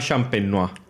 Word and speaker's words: champenoise: El método, en el, video del lunes dije el método champaña, no, el método, soy un pendejo, perdónenme. El método se llama champenois. – champenois. – Y se escribe champenoise.